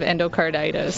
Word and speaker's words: endocarditis: You had a severe form of endocarditis. Is that how you say it? – Yeah, infective endocarditis.